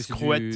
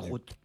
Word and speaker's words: Skroet: Scrut, ça s'écrit S-K-R-O-E-T. Je le dis parce que Scrut. C'est du coup, c'est Scrut. – Et Balg, B-A-L-G.